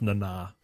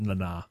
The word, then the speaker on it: Nana: It's pronounced Nana. I've heard her pronounce it Nana. You got to say it fast, Nana.